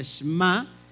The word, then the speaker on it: Shema: Lo que tú ves en pantalla, bueno, se dice Shema, pero en realidad se pronuncia Shema.